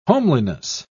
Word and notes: homeliness: British English

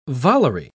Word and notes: volery: British English

